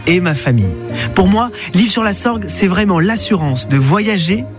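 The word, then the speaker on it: L'Isle-sur-la-Sorgue: Ah, notez bien qu'on dit l'île sur la Sorgue, mais que l'île s'écrit I-S-L-E.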